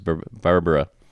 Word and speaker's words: Barbera: Well, no, you actually said it right. Apparently, that's how he pronounced his name was Barbera.